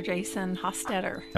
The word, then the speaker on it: Jason Hostetter: Our guest is Mr. Jason Hostetter.